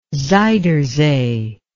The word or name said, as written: zuyder zee